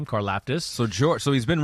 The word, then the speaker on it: Karlaftis: Yeah, number four edge rusher George Karlaftis yeah.